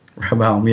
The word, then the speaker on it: Rehoboam: How do you say Rehoboam in English? Rehoboam? – Rehoboam, yeah.